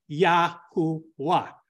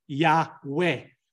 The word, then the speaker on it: Yahuwah: So ancient vowel systems would cause you to pronounce the name Yahuwah. Modern translators or modern vowels will pronounce it Yahweh. But we have studied in depth that the proper pronunciation is Yahuwah, not Yahweh, not Yehovah.